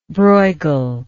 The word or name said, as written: brueghel